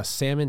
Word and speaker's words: Salmon: All around they had we had one dude Salmon, is that how you pronounce his last name? – Yeah, it's just like the fish. – Yeah, Salmon, he had 3 bombs.